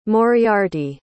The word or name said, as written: moriarty